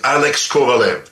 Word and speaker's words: Alex Kovalev: On avait tendance à y aller un petit peu à la russe, Alexei Kovalev, et lui a dit non, moi je travaille en Amérique du Nord, alors ça va être Alex Kovalev. C'est le nom que je vous demande de prononcer, et c'est exactement ce qu'on a fait.